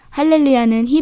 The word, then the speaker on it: 할렐루야: 할렐루야는 히브리어를 발음한 것이고 알렐루야는 그리스어를 발음한 것입니다. 그리스어에는 히읗 발음에 해당하는 문자가 없어서 그 소리에 가까운 이응 발음을 사용한다고 하네요.